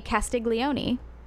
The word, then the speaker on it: Castiglione: She is the Countess di Castiglione.